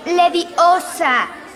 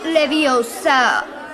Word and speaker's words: Leviosa: Además, no se dice así. Es Leviosa, no Leviosa.